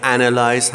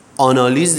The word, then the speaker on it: analyze: مثلا اگر کلمه analyze هست، آنالیز دیگه بهش نگید.